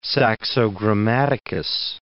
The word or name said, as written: Saxo Grammaticus